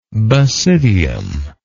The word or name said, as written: basidial